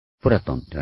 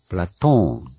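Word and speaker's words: プラ ト ーン (Πλάτων): プラトンではなく、プラトーンなんですね。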